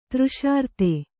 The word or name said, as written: ತೃಷಾರ್ತೆ